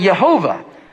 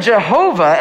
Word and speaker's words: Yehovah: And the more proper way to pronounce it, as I mentioned last week, is actually Yehovah. Because it is built on the word Yahweh. It should be pronounced Yehovah. And that's how it is pronounced usually in scholarly circles, with these vowels from Adonai merged with this word Yahweh.